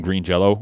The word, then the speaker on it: Green Jelly: It's pronounced Green Jello, but it's spelled Green Jelly. – Oh, really? – Good for them. – Yeah. Well, because they got like a cease and desist from Green Jello when they first came out, from the Jello Corporation or whatever.